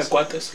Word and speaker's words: cuates: It's carnitas los Juates, I think, or some shit. How do you say twins again? – Yeah, cuates.